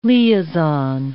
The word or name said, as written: liaison